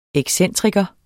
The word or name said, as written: EXCENTRIKERNES